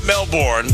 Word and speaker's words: Melbourne: It's not Melbourne, it's Melbourne. Or is it Melbourne? – Melbourne. Or Melbourne. – Oh, Melbourne. – Apparently they don't pronounce the R.